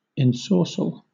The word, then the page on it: ensorcell: British English